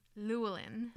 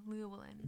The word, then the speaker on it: Llewellyn: Llewellyn. Yeah. – Four L's in it. – Yep. Two double L's, and the first one is pronounced almost as an L mixed with a Y, Llewellyn.